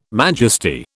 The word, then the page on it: majesty: British English